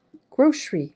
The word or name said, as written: grocery